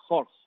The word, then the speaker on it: Horch: A ver si me sale otra vez en alemán. Horch. Si lo ves, se escribe Horch, o sea, H-O-R-C-H.